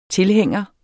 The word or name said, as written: tilhænger